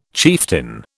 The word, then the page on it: chieftain: British English